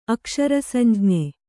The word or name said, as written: ಅಕ್ಷರಸಂಜ್ಞೆ